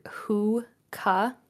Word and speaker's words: hookah: Am I pronouncing that right? – It's more of like an A sound, like hookah.